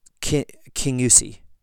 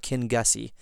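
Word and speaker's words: Kingussie: And they're their father, this Gilacatmore, becomes the parson of King Usi. I think I'm saying that right. It's not King Gussie. King Usi.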